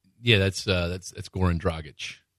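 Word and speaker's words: Dragić: D-R-A-G-I-C. Is it my fault because I pronounced it like the American letters that they are, and you jump all over me because it's Strogic.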